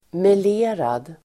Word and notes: melerad: [mel'e:rad]

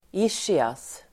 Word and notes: ischias: ['isj:ias]

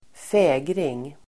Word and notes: fägring: ²f'ä:gring

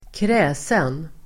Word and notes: kräsen: [²kr'ä:sen]

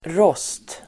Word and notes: rost: [rås:t]

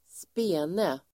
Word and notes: spene: [²sp'e:ne]

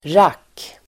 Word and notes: rack: [rak:]

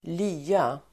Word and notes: lya: [²l'y:a]

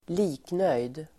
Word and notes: liknöjd: [²l'i:knöj:d]

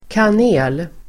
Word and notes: kanel: [kan'e:l]

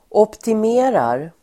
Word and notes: optimerar: åptim'e:rar

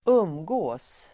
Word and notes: umgicks: [²'um:jik:s]

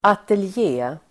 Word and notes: ateljé: [atelj'e:]

